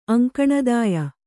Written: ಅಂಕಣದಾಯ

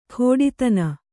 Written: ಖೋಡಿತನ

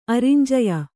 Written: ಅರಿಂಜಯ

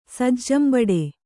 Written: ಸಜ್ಜಂಬಡೆ

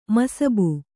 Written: ಮಸಬು

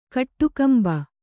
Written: ಕಟ್ಟುಕಂಬ

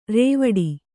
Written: ರೂವಿಡು

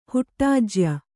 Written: ಹುಟ್ಟಾಜ್ಯ

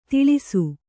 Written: ತಿಳಿಸು

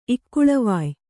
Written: ಇಕ್ಕುಳವಾಯ್

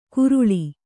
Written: ಗುರುಳಿ

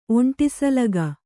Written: ಒಂಟಿಸಲಗ

